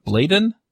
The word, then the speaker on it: Bladon: I should have asked how to say his last name, Bladon.